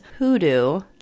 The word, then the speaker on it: Gudu: And I guess the G is pronounced like an H, so it's Hoodoo Cubos.